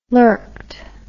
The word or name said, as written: lurked